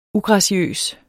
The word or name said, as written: ugraciøs